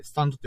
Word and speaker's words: 幽波紋: うんうんうん、まあ実際、スタンドの漢字名で言うならば、幽霊の幽に波紋って書くんですよ。幽波紋って書いて、スタンドって読むんですよ。